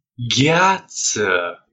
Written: gheata

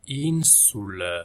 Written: insula

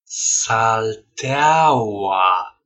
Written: salteaua

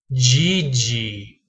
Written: gigi

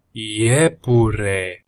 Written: iepure

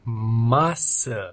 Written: masa

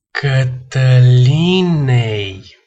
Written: catalinei

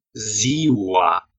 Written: ziua

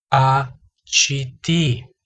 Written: a citi

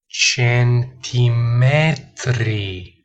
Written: centimetri

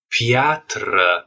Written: piatra

